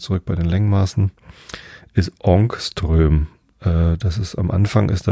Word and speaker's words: Ångström: Ich glaube, das A mit dem Kringel oben drauf wird O ausgesprochen. Ångström. So, ähm, ein Ångström sind 0,000000001 Meter, also 10 hoch minus 10.